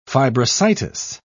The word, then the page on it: fibrositis: British English